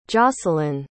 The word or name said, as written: jacelyn